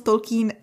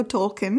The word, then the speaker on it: Tolkien: Správně výslovnost Tolkien, uh, omlouvám Tolkien? se, když budu říkat Tolkien, protože jsme na to všichni zvyklí prostě, ale správně a je Tolkien. Tolkien.